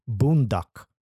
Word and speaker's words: bundok: Boondocking is an American expression derived from the Tagalog word bundok, which means mountain. And for those of you who may question my fobby accent on that word, I assure you the pronunciation is correct.